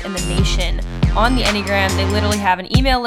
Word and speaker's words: Enneagram: Yes, that is how you pronounce it if you've been seeing on an Instagram, and I've been wondering how the heck do you say that word. Yes, it is Enneagram.